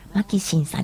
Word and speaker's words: MAXIM: やっぱりマキシムさんね、マキシンさんですよ。 MAXIM と書いてマキシンと読みますが、こちらのマキシンさんの帽子は神戸の帽子なので、神戸のイメージ、石畳はね、なんかフランスとなんか似てるなというふうに思ったりするので、今回のマキシンさんの香りはマキシンっていうタイトルで、